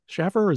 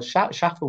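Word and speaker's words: Shaffer: Schaffer, is that the right pronunciation? – Yes.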